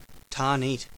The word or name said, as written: Tarneit